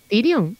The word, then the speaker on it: Tyrion: Sirion, ¿y cómo se dice? ¿Tyrion? ¿Tyrion? ¿Tyrion? ¿Tyrion? – ¿Tyrion? – Vale.